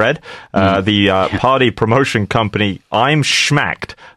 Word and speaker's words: Schmacked: Schmacked.